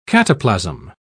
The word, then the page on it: cataplasm: British English